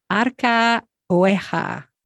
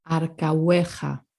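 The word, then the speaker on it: Arcahueja: Okay, the silent H. Arca hueja. – Arca hueja. – Arca hueja. See, we did this one earlier with the J. So now we've got two difficulties in this one. Arca hueja.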